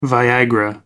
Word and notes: viagra: American English

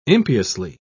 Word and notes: impiously: British English

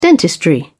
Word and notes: dentistry: British English

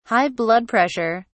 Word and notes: high blood pressure: British English

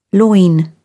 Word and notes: loin: British English

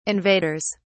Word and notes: invaders: British English

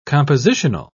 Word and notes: compositional: British English

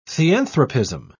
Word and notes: theanthropism: British English

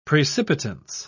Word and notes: precipitance: British English

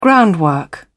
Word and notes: groundwork: British English

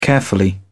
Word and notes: carefully: British English

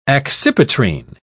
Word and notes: accipitrine: British English

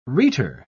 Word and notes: rhetor: British English

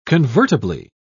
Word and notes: convertibly: British English

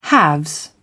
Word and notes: haves: British English

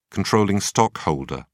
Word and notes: controlling: British English

